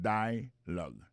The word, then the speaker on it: dialogue: Alors, mot dialogue, ça c'est dialogue. Ça nous a remarqué là, nous a remarqué que l'accent tonique là tombé sous premier syllabe là.